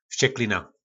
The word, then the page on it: Vsceklina: Po našimu